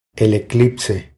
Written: El eclipse

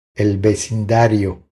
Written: El vecindario

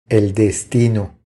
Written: El destino

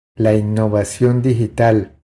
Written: La innovacion digital

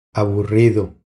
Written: Aburrido

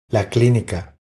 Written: La clinica

